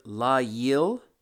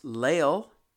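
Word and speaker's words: layil: And Strong's Concordance, number 3915, this is the word la-yil. It can also be pronounced Lael, and it can also be pronounced La-Yel-A.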